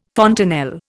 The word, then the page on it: fontanelle: Indian